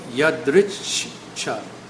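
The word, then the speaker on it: yadṛcchā: So, yadrichcha. The two C's are actually pronounced, so it's a ch. Almost like a stutter.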